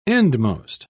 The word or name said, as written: endmost